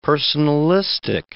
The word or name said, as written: personalistic